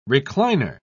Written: recliner

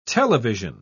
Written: television

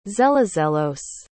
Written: zelozelos

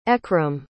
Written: Ekram

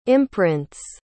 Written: imprints

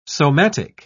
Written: somatic

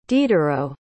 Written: diderot

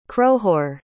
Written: crohoore

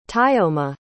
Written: taiomah

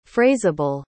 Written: phraseable

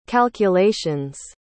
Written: calculations

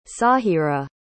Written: saahira

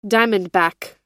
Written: diamondback

